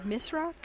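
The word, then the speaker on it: Misrach: And yet there were other people who ended up doing some, for example, Richard Misrock, is that how you say his name? – Yes.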